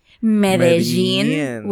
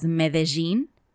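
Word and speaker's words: Medellín: So, the happy news of the week is in Colombia, in the city of Medellin. Wait, in Colombia, they pronounce it as Medellin.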